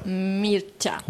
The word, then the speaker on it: Mircea: Mircea.